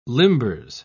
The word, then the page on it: limbers: British English